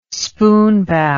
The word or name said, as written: spoonbow